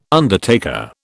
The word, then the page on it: undertaker: British English